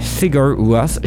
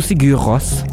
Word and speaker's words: Sigur Rós: Je ne sais pas si je le dis bien. – Oui, Sigur Rós. – Oui, tu le dis bien. Ouais. – Ou Sigur Rós. – Ça dépend des gens, mais c'est pas mal ça.